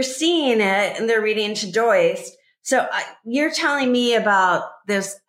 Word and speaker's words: Todoist: it looks like Todoist, T-O-D-O-I-S-T. And it's pronounced Todoist, list, Todoist.